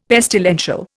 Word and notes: pestilential: Indian

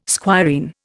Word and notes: squireen: Indian

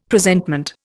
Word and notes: Presentment: Indian

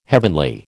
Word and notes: heavenly: American English

